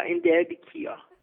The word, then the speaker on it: Indibakaya: Okay, am I saying this right? How do you say it? Indibakaya? – Yeah, Indibakaya.